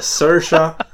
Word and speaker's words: Saoirse: Jag vet hur det uttalas, men när jag ser på hennes namn så låter det inte så. Det är Saoirse Ronan för Ladybird.